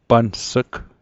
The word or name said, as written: pans'g